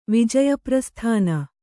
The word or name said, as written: ವಿಜಯಪ್ರಸ್ಥಾನ